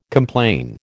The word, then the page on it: complain: American English